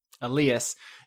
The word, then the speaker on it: Elias: I'm going to keep messing this name up because it's spelled Alias. – Elias.